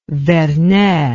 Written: vernet

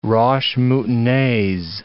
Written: roches moutonnees

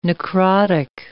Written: necrotic

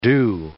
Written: doux

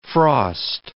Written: frost

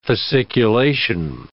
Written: fasciculation

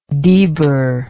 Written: debir

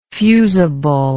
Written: fusibly

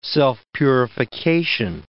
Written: self-purification

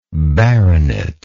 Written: baronetical

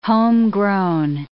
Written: homegrown

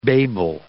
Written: babel

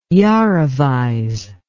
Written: yarovize

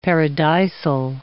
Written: paradisal

